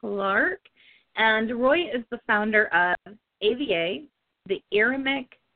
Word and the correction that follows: Aramaic: Oh, I'm saying that incorrectly, Anna. – Right. – Tell us what it is. – It's the Aramaic Vernacular Association.